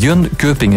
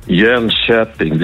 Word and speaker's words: Jönköping: Het is niet Jönköping, hè? Dat is totaal verkeerd. Hè? Wat moeten we dan wel zeggen? – Ja, het is eigenlijk Jönköping. Jönköping, dus de K voor de umlaut wordt heel zacht uitgesproken in Zweden.